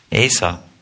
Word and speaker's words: Asa: Did I pronounce that correct? Asa or Asa? Asa.